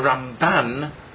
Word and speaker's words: Ramban: The Ramban, the accent is always on the second syllable. In the Rambam, the accent is on the first syllable.